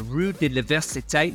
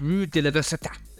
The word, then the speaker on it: Rue de l'Université: It's called Rue de l'Université.